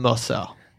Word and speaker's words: Mercer: My name is Captain Mercer. Oh, how do you spell that? – M-E-R-C-E-R? Or no, Captain, C-A-P-T-A-I-N.